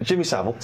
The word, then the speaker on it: Savile: Am I saying that right? – Jimmy Savile. – Savile. – Say his name right, please.